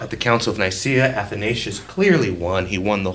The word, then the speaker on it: Athanasius: Athanasius, not Athanasius. Athanasius is how you pronounce his name.